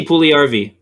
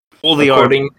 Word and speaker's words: Puljujärvi: I mean, yeah, Jesse Puljujärvi. That's how you say it. – Puljujärvi. Got it. – According to Hockey Reference, it's Puljujärvi.